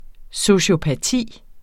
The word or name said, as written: sociopati